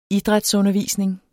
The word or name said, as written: idrætsundervisning